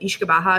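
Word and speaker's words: uisce beatha: It's kind of written similar to like Uski Betha, but Ishkabaha is the pronunciation.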